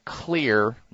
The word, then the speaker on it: Xlear: And I would recommend you try a good sinus nasal wash called Clear Nasal Spray or Nasal Wash, Clear Nasal Wash. And that's spelled X-L-E-A-R. The X is for xylitol.